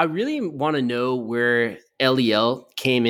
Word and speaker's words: ELIEL: ELIEL.